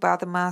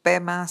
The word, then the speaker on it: Padma: yo recito el mantra Om A Vajra Guru Padma Siddhi Hum, que los tibetanos pronunciamos Om Ahung Bensa Guru Pema Siddhi aspirando las H iniciales que para mí es verdaderamente Padma Sambhava y la bendición de todos los maestros en forma de sonido.